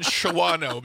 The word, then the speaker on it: Shawano: S-H- A W A N O. How do you pronounce it? – Shawano? – I say Shawano. – Do you really? Do you know that my dad and stepmom live there? – Yeah. I didn't know that, but they live in Shawano because sorry, I'm a child of phonics. – No, it's pronounced Shawano.